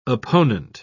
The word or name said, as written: opponent